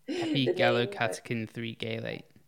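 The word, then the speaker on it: epigallocatechin-3-gallate: I'm not going to try and pronounce the epigallocatechin 3-gallate.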